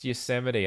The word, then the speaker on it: Yosemite: She said, "That's pronounced Yosemite."